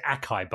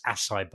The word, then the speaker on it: acai: I think I pronounced it acai bowl, but it's acai bowl, and I think it might be Hawaiian or Brazilian or something they come from.